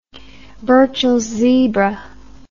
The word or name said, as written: burchells zebra